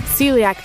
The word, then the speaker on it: celiac: So unless you have, how do you pronounce this? Celiac disease? Let me get a pronunciation. I think I'm close on this one. Celiac? – Celiac disease. – Celiac disease. Okay.